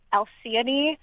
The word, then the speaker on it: Alcyone: So one of my favorites in this is that of Alcyone and Ceyx. Which is how those are pronounced.